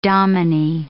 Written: dominie

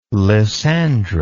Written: lysandra

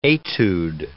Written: etude

